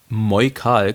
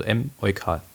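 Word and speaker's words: Em-eukal: Der Clou dabei, Moikal klang zu sehr nach Apotheke. Daher wurde das M geschrieben, wie es ausgesprochen wird. Also M-Eukal.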